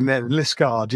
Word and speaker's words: Liskeard: Liskeard, yeah. – Liskeard. Yeah. It's got that rogue E in there.